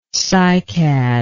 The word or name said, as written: cycad